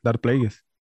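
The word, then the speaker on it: Darth Plagueis: Ahora que usted nos estaba dando las recomendaciones de, por ejemplo, creo que lo voy a, pronunciar mal, Dark Plagueis, creo que lo voy a, pronunciar mal, ¿verdad? – No, está bien, dar Players.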